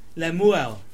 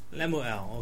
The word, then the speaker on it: Lemuel: Can somebody read the name pronounced properly? Lemuel. Lemuel, I think it's out. Chapter 31 verse 1. Lemuel. Somebody read it? Who was reading it? Huh? King Lemuel. Lemuel, okay.